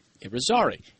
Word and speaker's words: Yrizarri: His last name is spelled Y R I Z A R R I. Y R I Z A R R I, and that's pronounced Irizarry.